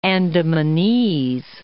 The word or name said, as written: Andamanese